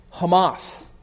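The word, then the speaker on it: Hamas (חמס): The Hebrew word here is pronounced Hamas.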